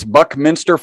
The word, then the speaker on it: Buckminster: Why did you put so much attention on this Buckmeister. I don't know if I'm saying that right, but the carbon 60 molecule. Let's dive into to what brought you here. – Yeah, so it's Buckminster Fuller, and there's a whole gamut of molecules called fullerenes, and they're named after Bucky Fuller.